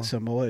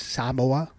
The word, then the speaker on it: Samoa: We've been saying Samoa. It's Samoa. – Yeah, Samoa. – Samoa. You gotta put an emphasis on the A.